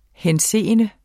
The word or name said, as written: henseende